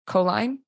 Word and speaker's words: choline: I saw on your Instagram about a study done in mice that you talked about choline. Is that how you say it? Choline? – Choline.